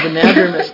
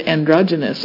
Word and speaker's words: anadromous: I just wanted to commend Beth on her pronunciation of anadromous, because the last time I heard it, they said androgynous.